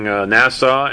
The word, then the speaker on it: Nassau: I'm just happy I got Nassau pronounced right. Or did I just mess it up again? – No, that was right.